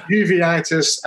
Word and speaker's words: uveitis: Four out of ten for those pronunciations. it's uveitis and eosinophilic esophagitis rheumatoid arthritis, and allergic disease.